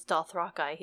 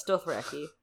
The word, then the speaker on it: Dothraki: But first, We have Missandei and Grey Worm entering the North for the first time, along with the Unsullied and the Dothraki, which George apparently says is Dothraki. He's wrong. – Yeah, it's Dothraki. Sorry, George. I'm overruling you. I know it's your series, but it's not happening.